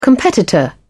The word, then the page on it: competitor: British English